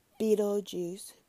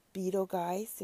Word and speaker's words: Betelgeuse: which is aligned with Betelgeuse, Betelgeuse, if you want to call it, is either pronunciation is correct, which is a revered star of Orion.